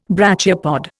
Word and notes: brachiopod: Indian

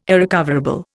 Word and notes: irrecoverable: Indian

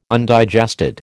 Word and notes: Undigested: American English

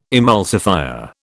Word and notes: emulsifier: British English

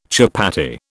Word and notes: chapatti: British English

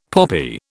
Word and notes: poppy: British English

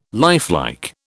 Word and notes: lifelike: British English

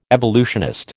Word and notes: evolutionist: American English

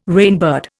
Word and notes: rainbird: Indian

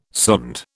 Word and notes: sonde: British English

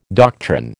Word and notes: doctrine: American English

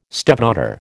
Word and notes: stepdaughter: American English

